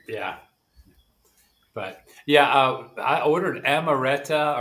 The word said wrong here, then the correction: Amaretto: or how do you say that? Isn't it a liquor? – Yeah. Amaretto. – Amaretto.